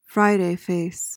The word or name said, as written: Friday face